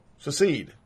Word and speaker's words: secede: I could give a fuck if you succeed. Succeed. There we go. Secede. No, secede. I'm not even saying it right.